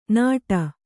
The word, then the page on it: ನಾಟ: Kannada